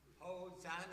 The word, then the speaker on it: Hosanna: And then rhymes with Anna. – Hosanna.